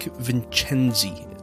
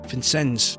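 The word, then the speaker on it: Vincennes: a narcotics sergeant named Jack Vincenzi. Did I say that right? – Vincenzi.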